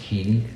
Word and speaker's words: Heaney: And just so you know, it's pronounced Heaney, as the emphasis on the E.